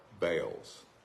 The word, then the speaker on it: Baal: And you have followed the Baals. There's all kind of different ways to pronounce that, but I call it Baal because that's the way where I'm from. That's what they call it.